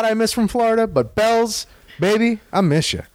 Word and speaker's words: Bealls: There's this place in Florida called Bell's, but it's spelled B-E-A-L-L-S.